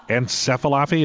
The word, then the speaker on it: encephalopathy: It's encephalopathy. Is that right? – Yeah, that's pretty close.